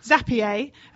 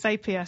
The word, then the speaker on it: Zapier: Well, somebody I know says Zapier, and I call it Zapier. So, you know, it's all in how you say it, really.